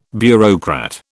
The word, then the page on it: bureaucrat: British English